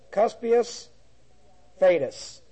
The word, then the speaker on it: Cuspius Fadus: The first procurator is Cuspius, and I'll spell that, C-U-S-P-I-U-S. Cuspius Fadus, F-A-D-U-S.